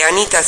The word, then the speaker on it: Anitta: E c'ha due T. – Ma se ne pronuncia sempre una. Allora, Anitta è veramente famosa cantante e ballerina brasiliana,